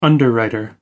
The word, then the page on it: underwriter: American English